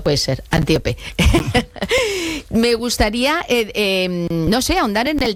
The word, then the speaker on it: Antíope: ahondas mucho en la relación de Teseo con Antílope, pero lo he dicho mal el nombre, es Antíope.